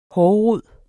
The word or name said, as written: hårrod